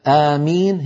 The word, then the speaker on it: آمين: آمين هي أشهرها، آمين هذا أشهرها. في لهجة ثانية آمين بتشديد الميم آمين، وفيها لهجة ثالثة أمين بوزن فعيل، لكن أشهرها آمين.